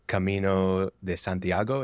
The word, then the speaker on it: Camino de Santiago: One was the cult experience, and then the other one was the Camino de Santiago. Is that what it is? – Yeah, that's how you say it.